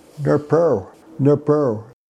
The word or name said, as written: nrprw